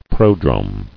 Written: Prodrome

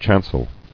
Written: Chancel

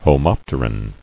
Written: Homopterous

